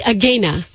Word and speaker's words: Agena: But it should be Agena. – Agena. Yeah, I'll have him recut the end. So instead of Agena to rhyme with Arozarena.